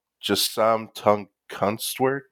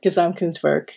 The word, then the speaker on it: Gesamtkunstwerk: is the concept of Gesamtkunstwerk. And I messed that up. – A total work of art, Gesamtkunstwerk.